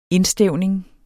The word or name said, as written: indstævning